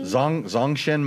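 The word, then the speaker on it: Zongshen: The NC450 Zagashin motor. – Hey, you pronounce it as good as I could. Say it again. – Zagashin? – Zongshin motor.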